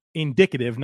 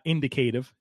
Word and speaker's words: indicative: It's indicative, not indicative. I got it. Pronunciation police, put the handcuffs away. I'm learning. It's indicative.